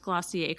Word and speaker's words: Glossier: I'm actually surprised you pronounced Glossier correctly.